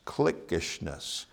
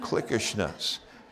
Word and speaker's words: cliquishness: Let me share with you the third obstacle in being a disciple, committed disciple for Christ, the obstacle of cliquishness, cliquishness. And here's how you spell it, C-L-I-Q-U-I. Shness, that's how you spell it, clickishness.